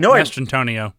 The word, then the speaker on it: Mastrantonio: Mastrantonio.